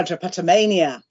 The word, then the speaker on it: drapetomania: I mean, I was wondering if you might be able, for example, to tell us about, is it pronounced drapetomia? – Ah, drapetomania. – Excuse me, yes.